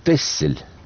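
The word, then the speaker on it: Texel: Дело все в том, что самый ближний к Голландии остров, который пишется Тексель, а читается Тессель, в 15 веке получил статус и права города.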